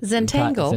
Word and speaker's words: Zentangle: So one of the programs you briefly mentioned, Think Zentangle, is that how you pronounce it? – Zentangle.